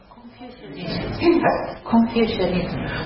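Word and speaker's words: Confucianism: 儒教はこれ何て発音するんですか、誰か英語ができる人いますか。 – コンフューシニズム、コンフューシニズムというそうですね、なんとかイズム。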